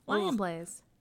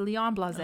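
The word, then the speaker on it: Lionblaze: Lion Blaze. – Gross. – No, you're saying it wrong. It's Leon Blaze.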